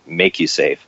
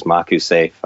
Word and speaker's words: MakuSafe: So we kind of did a little play on the word and we put a line over the A and pronounce it MakuSafe. But anybody that comes to me and says MakuSafe, I go with that as well.